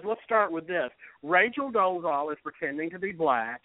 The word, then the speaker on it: Dolezal: The Caitlyn Jenner and the Rachel, and how do you pronounce her last name? – Dolezal.